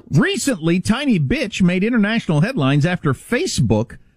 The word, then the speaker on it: Bitche: I was going to pronounce it Bitche because it's B-I-T-C-H-E, but the E is silent. It's actually pronounced Bitche, I believe.